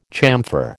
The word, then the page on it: chamfer: American English